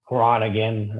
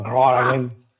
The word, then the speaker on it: Groningen: So Amsterdam, Rotterdam, Groningen. I'm saying it wrong. Groningen.